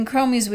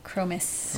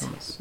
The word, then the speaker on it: chromis: That meant sometimes the spotted mandarin would not get any food and the blue-green chromis would eat all of it up. I said that wrong again, didn't I? Chromis. – Chromis is how I've usually heard it.